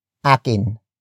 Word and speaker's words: akin: And that is at akin. Akin. Akin. Akin is spelled as A-K-I-N.